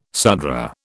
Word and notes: Sudra: British English

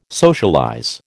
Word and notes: socialize: American English

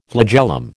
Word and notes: flagellum: American English

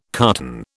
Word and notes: carton: British English

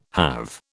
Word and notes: have: British English

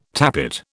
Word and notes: tappet: British English

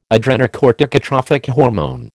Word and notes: adrenocorticotrophic hormone: American English

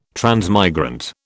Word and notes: transmigrant: British English

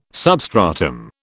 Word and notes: substratum: British English

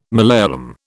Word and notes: Malayalam: British English

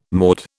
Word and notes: maud: British English

